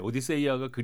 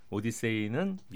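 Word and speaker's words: Odysseia: 그러니까 주인공은 오디세우스고 작품 이름은 오디세이, 오디세이아 이렇게 이렇게 되는데 오디세이아가 그리스 원어의 충실한 발음이라면 오디세이는 영어식 발음이다 이렇게 보시면 되겠습니다.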